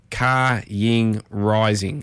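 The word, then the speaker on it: Kaying Rising: If you're wondering how it's pronounced, it's spelled Car Ying Rising, so K A, then Y I N G, then Rising, so Car Ying Rising.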